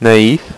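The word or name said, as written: naïf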